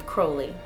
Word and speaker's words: Crowley: We pronounce his name Crowley. – I've been pronouncing it wrong for quite a while.